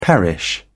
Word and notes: perish: British English

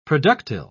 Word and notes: productile: British English

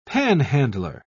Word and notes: panhandler: British English

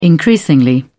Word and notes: increasingly: British English

